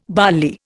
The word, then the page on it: bally: Indian